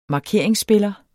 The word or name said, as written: markeringsspiller